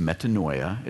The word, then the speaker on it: metanoia: The Greek word that's translated repentance in the New Testament, that 23% of the Bible, is pronounced metanoia.